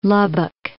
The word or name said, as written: Lubbock